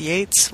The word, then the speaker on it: Yeats: Yeats.